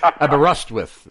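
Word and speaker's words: Aberystwyth: And did I remember how to pronounce Aberyst with correctly? You did it well. The rust is it build it round the rust. – Aberyst with. So the emphasis is on the rust.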